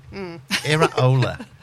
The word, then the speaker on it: Iraola: And this is how you pronounce it. – Iraola.